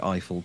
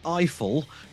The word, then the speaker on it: Eiffel: And this caught both you and I off guard, I think, because as far as we were concerned, we were watching a movie called Eiffel, rhymes with trifle, starring some French dude and Emma Mackey from Sex Education.